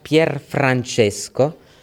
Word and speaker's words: Pier Francesco: C'est simple, tout droit, Pier Francesco avec la R.